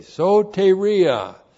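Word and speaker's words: soteria (σωτηρία): S long O T long E R I A, soteria.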